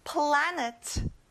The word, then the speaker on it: planet: 행성, planet. 자, 에 발음이 아니라 벌려서 아 발음이 나요. Planet.